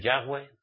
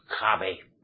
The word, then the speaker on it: Yahweh: Y luego muchos de vosotros, que ya os habéis apartado, ya no podéis pronunciar el nombre Yahweh, sino hacéis Jave.